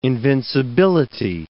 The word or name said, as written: invincibility